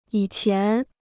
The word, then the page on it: 以前: yǐ qián